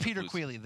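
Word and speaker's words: Peter Queally: If I mispronounce it. – It's Peter Quealy, the showstopper from SBG.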